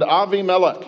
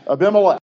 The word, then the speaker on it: Avimelech: And Avimelech, yes, I know, we ordinarily pronounce that Abimelech.